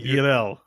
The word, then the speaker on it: Yurel: My dear Yurtle. Is it Yurtle? How do you say it? – Yurel. – It sounds like Yurtle.